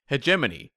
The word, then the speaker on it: hegemony: Hegemony.